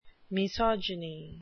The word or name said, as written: misogyny